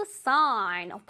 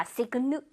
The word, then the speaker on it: sign: 이것도 마찬가지로 sign 할때 G 소리를 내면 안 됩니다. Signal 아니에요. Sign.